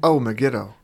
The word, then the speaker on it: Megiddo: Robert Thorne is meeting Father Brennan, and he tells Robert that he needs to go to Medigo. – Megiddo. – Megiddo, an old city in Jezreel, and see a man named Bunnenhagen.